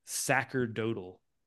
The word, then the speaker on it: sacerdotal: So, and it says there's this phrasing they use called sacerdotal. – Yeah. – Well, it's both. According to Wiki, it's both pronunciations are proper.